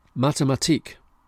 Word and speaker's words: Mathematik: Mathematik, stress on the last syllable.